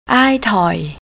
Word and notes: aytoi: thai

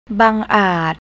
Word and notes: bangart: thai

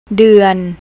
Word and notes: dern: thai

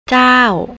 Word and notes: jaow: thai

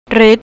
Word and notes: rid: thai

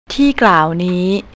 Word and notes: teeklawnee: thai

